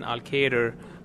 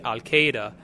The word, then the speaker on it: Elkader: Well, one of the sort of the ironic things is that in pronouncing Al-Qaeder, people generally say Al-Qaeda, and I always like emphasize to people that R in Al-Qaeder is not a silent R. Please pronounce it.